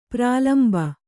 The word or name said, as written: ಪ್ರಳಂಬ